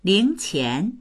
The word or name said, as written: ling2qian2